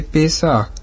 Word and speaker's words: Песах: Пасха, или как произносят евреи, Песах, означает «быстрый переход», то есть чудесный переход Израиля через Черное море.